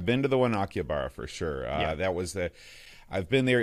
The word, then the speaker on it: Akihabara: is the three-floor store in Akihabara. Am I saying that right? – Akihabara.